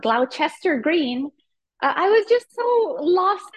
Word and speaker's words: Gloucester Green: It is that stop you wanted, but it's pronounced Gloucester Green.